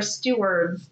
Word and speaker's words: Stewart: So like Miss Sneed says, I think she pronounces it more with a D. – Okay, see, yes. – Right? Yes. I could not tell if it's supposed to be Stewart or Steward because depending on who's saying it, you know, just don't know.